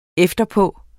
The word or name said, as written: efterpå